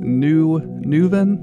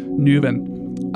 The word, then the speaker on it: Newhvn: That one was from the band A Burial at Sea in collaboration with an artist called Neuvin. I think that's how you say it. N-E-W-H-V-N. That's one of those newfangled millennial spellings. It's like taking all the consonants out of the restaurant's name. Neuvin.